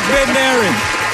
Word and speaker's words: Bednarik: Benarchik? Is it Benarchik? – That's close enough. We'll do it. Yes. Chuck Bednarik.